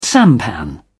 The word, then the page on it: sampan: British English